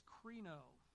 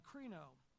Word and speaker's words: krino: So when we look at Matthew 7, verse 1, judge not that you be not judged, the word is krino, pronounced, as you see the spelling there is the K-R-I-N with the O with the accent mark over it, krino.